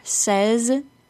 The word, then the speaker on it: says: The word say, S-A-Y, is pronounced with a long A. Can you hear it? Say. The words says and said are pronounced with a short E sound.